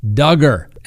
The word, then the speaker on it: Duggar: Next on the docket, let's talk about Josh Duggar. And for all of those that hate it, that's right, rhymes with hugger, okay?